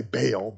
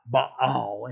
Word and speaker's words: Baal: We sometimes say Baal, but it's actually two syllables, Baal in Hebrew.